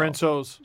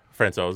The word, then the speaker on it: Francouz: Francouz, supposedly. – Francouz is how you say it? – Yeah, I learned that very late in the game.